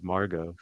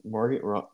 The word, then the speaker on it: Margot: It's Margot. – Oh, Margot. – The T is silent.